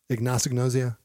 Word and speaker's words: anosognosia: Did I say that right? Anosognosia? – Very anosognosia. Close. Yeah.